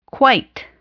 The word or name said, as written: quite